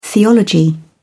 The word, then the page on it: theology: British English